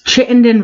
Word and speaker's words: Chittenden: The T is pronounced in Vermont. – Yeah, I mean, why don't you say it for us? – It's Chittenden, Vermont.